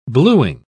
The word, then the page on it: blueing: British English